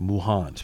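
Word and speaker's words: ∞: It's just like a symbol, but it's pronounced Muhan.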